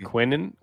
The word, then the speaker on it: Quinnen: You mean Quinnen? Quinnen Williams? – Quinnen. I don't know how to say his name. – Really not hard. Quinnen.